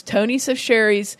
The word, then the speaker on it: Tony Chachere's: I used a good Creole seasoning, and it's Tony Sachier's Creole seasoning, and it looks like it says Tony Chachere's, but it's Tony. I had to look up the pronunciation on YouTube because I wanted to be able to say it right. They have a really hilarious commercial on YouTube, but it's Tony Sachere's Creole seasoning. You need a half cup of that.